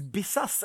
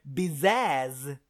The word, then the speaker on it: Bizaz: Och det är faktiskt Bizaz, eller som Yvonne säger, Bizaz med Ä.